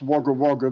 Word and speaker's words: Wagga Wagga: It is, on a technical point, it's pronounced Wagga Wagga, but yes, I was in fact born here, and I am broadcasting from there right now.